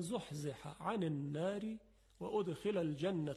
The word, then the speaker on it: زحزح: خب در این آیه فمن زحزح، دو تا حرف حاء هست که از وسط حلق تلفظ میشه، زحزح، و بعد از اون یک عین هست، عن النار، زحزح عن النار، زحزح عن النار.